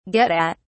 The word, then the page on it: get at: British English